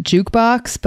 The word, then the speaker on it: JQBX: So there's a new one of those out called Jukebox, but it's spelled... Are you ready? J-Q-B-X.